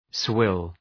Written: swill

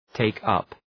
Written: take up

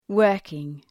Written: working

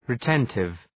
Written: retentive

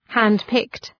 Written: hand-picked

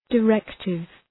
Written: directive

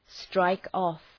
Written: strike-off

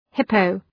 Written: hippo

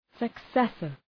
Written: successor

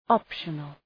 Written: optional

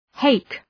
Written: hake

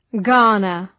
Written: garner

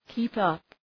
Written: keep up

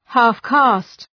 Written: half-caste

